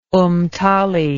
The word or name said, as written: Umtali